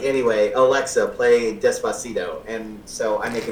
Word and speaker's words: Despacito: Oh, no, it's Despacito.